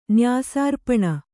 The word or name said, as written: ನ್ಯಾಸಾರ್ಪಣ